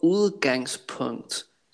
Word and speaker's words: udgangspunkt: Kun ordet udgangspunkt. I'll try to pronounce this soft D sound a little stronger.